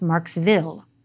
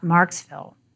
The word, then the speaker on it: Marksville: For example, the small town of Marksville is pronounced Marksville, not Marksville, by its largely Cajun population.